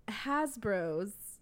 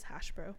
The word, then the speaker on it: Hasbro: So, Hasbro's came out this week, and is that how you say it? Hasbro's? – Mm-hmm. Yeah. Hasbro. Right. I thought it was Hashbro.